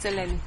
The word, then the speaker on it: Eleni: Eleni, am I saying that right? – Yes, it's Eleni. – Yes, I got it right. So it's Greek for Helen. Did you say that? – Yes, it's a good version of Helen.